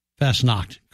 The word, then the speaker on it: Fastnacht: Fastnacht Day. Is that how you say it? – Fastnacht, correct.